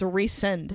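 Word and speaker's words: Rhysand: First of all, Ryshand is what I call him, but his name is Ryshand. – No, it's not even Shand. It's Resend.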